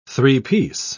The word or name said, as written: three-piece